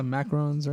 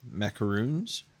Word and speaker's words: macaron: I did purchase a dozen is that you spell it macarons, right? Is it macarons? – Macaroons? – What is that macaroons? There's only one O. – Macaron. – It's macarons, yeah.